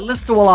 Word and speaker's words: Listowel: And by the way, a guy from, how do you say it? Listerl? – Yeah, Listowel, Ontario.